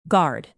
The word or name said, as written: guard